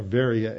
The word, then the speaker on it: Veria: For example, the Bereans in Acts 16, in Berea or Veria, as it's pronounced in Greek, when Paul and Silas go there and they're teaching in Berea, Paul says, and they didn't just take Paul's word at it.